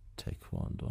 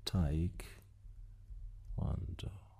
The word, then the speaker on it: تکواندو: نه تکواندو آتون نیست، پیداش میکنم، ولی فکر کنم تایک اینجوری باشه، تایک واندو. سرچ بزنم پیدا میکنه خودش. آیناش تلفظ دقت کن عزیزان، تایکواندو. ببینید تایکواندو به این صورت هستش، و کراتی.